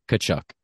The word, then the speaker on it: Tkachuk: The T is very silent. It's Tkachuk.